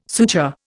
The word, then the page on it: suture: Indian